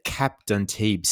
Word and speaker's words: Cap d'Antibes: The Cap d'Antibes Stakes for the three-year-old fillies over the 1100. How'd I go with the pronunciation? – Au français, very good, mate.